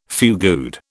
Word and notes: fugued: British English

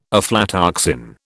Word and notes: aflatoxin: British English

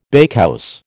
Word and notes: bakehouse: American English

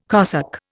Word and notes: cosec: Indian

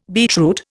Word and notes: beetroot: Indian

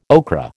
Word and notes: okra: American English

